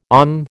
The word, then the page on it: OM: American English